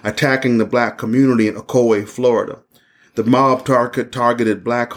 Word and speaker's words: Ocoee: Ocoee, right, exactly. Get the right pronunciation. The Ocoee Massacre in 1920.